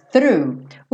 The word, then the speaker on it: through: Lembrando que para fazer o through, o GH ali do final você pode fingir que não existe, porque você não vai ter que pronunciar, e o through tem que começar aqui com o f, f, f, f, tá? TH é linguinha na ponta do dente, não tem jeito.